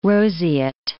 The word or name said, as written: roseate